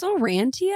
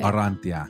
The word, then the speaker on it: Orrantia: It's Orrantia, I think. – I've been saying it wrong for years. – But honestly, I think Orrantia is the proper way to say it.